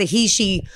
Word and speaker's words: Heche: It's Heche, but I always hated that because it sounded like hate.